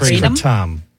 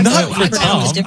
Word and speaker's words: kratom: It's Kratom. Go to Thailand. It's Kratom. – It's not Kratom.